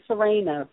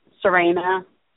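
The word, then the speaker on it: Suraina: This is Serena from Philadelphia. – Philadelphia. What's your name? – Serena.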